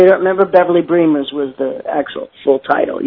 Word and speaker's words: Bremers: Don't Say You Don't Remember Beverly Bremers, pronounced like Dreamers.